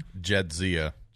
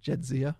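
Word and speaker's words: Jadzia: Jedzia. – Jedzia, okay.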